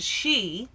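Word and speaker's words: sídhe: I was pleased with this movie to finally get a good pronunciation of the she, which, you know, has a D in it.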